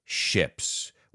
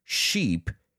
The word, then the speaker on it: ship: Obviously, idiomatic expressions that have to do with ships, which we now know are 20 meters and over. And please, let's pronounce that word. Ship. Ship. Es un e. Ship. Not sheep. Ship. Because a sheep is bah.